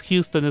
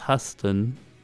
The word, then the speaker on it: Huston: Eh, no es Houston, es Huston. Por lo que veo aquí escrito. Coleman y No sé, o sea, salen muchos.